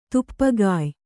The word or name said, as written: ತುಪ್ಪಗಾಯ್